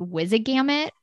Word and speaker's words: Wizengamot: So, is that how it's pronounced? Is it Wizengamot? – Wizengamot.